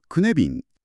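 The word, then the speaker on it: cynefin: はい、シュネフィンと綴って、ウェールズ語で居場所とか複数の帰属先といった意味を持つそうですね。発音はクネビン。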